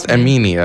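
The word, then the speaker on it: Amine: Yeah, I know Amini always gets mad. – It's not Amini. I think his name is Amin. – So why there is an E? – They just spell it that way.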